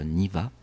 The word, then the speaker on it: Neve: And Neve? – Il répéta le nom bizarre dont la prononciation ressemblait à Niva,